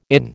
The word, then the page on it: IN: American English